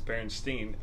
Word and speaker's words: Berenstain: Not Stein. It's Berenstain. I mean, it is German-ish, but it's Berenstain, and it's always been Berenstain, like that.